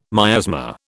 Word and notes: miasma: British English

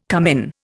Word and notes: cummin: Indian

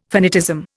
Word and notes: finitism: Indian

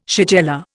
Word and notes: shigella: Indian

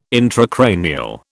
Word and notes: intracranial: British English

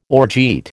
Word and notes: orgeat: American English

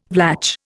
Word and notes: Vlach: Indian

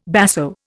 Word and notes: basso: Indian

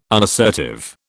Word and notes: unassertive: British English

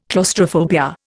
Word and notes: claustrophobia: Indian

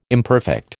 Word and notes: imperfect: American English

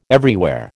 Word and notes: everywhere: American English

